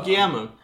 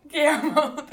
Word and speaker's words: Guillermo: Oh, Guillermo. – Guillermo, there we go.